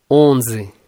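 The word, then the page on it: 11: Brazilian Portuguese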